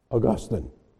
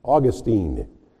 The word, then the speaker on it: Augustine: Now, I realize that the pronunciation of that name varies from one region in the United States to another region, so that I hear in Pittsburgh and oftentimes in the East, Augustine.